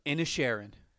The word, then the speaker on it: Inisherin: The Banshees of Inisherin.